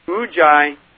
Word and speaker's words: Ujjayi: It's called Ujjayi Pranayam, and that's spelled U-J-J-A-Y-I, Ujjayi, and that's the Sanskrit word for it.